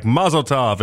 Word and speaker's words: mazel tov: So it's supposed to be like a almost like a rhymes with love at the back end of it, like a tov type thing, like a mazel tov.